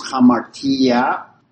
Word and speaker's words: hamartia: The first one is hamartia. Pronounce it with like an H. See that little, it's supposed to be a rough breathing in the Greek that makes it like an H sound. So it'd be H-A-M-A-R-T-I-A.